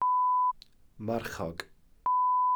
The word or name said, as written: marchog